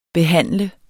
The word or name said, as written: behandler